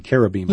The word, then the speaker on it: cherubim: And the original word, cherubim, it actually should be cherubim, yeah. – I guess. But we see the CH here in English. We tend to pronounce it with a ch, but it's a k.